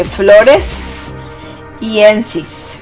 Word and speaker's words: floresiensis: Y bueno, también está el Homo florensiensis. Bueno, este Homo florensiensis, que lo pronuncie mal, como las flores, de hecho dice floresiensis, ¿ok?